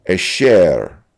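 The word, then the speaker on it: asher (אֲשֶׁר): The original Hebrew word here is pronounced asher.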